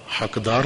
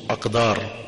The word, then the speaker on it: اقدار: بہر کیف عزیزوں نے لکھا کہ حقدار, یعنی موضوع سخن ہے انسانی حقدار. حقدار نہیں ہے موضوع, اقدار ہے کے ساتھ ہے جیمی ہے خے جیسے لکھتے ہیں.